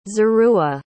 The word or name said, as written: zeruah